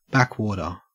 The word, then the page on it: backwater: Australian English